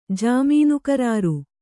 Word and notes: ಜಾಮೀನುಕರಾರು: kannada; jāmīnu karāru

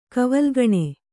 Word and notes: ಕವಲುಗಣೆ: Kannada

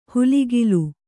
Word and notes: ಹುಲಿಗಿಲು: huligilu

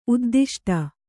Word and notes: ಉದ್ದಿಷ್ಟ: uddiṣṭa

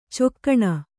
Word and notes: ಚೊಕ್ಕಣ: cokkaṇa; Kannada

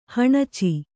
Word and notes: ಹುಣಚಿ: kannada

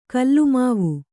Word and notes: ಗುಲಿಮಾವು: kannada

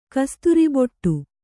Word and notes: ಕಸ್ತೂರಿಬೊಟ್ಟು: kannada

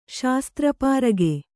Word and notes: ಶಾಸ್ತ್ರಪಾರಗೆ: Kannada

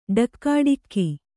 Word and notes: ಠಾಕುಟೀಕು: Kannada

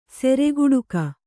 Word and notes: ಸಿರಿಗೇಡುಗ: Kannada

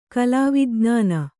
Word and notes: ಕಲಾವಿಜ್ಞಾನ: kalāvij'nāna

